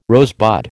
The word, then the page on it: rosebud: American English